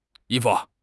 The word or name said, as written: yi1 fu5